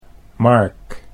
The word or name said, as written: Mark